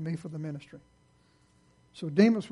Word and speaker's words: Demas: His name was Demas. Rhymes with demon.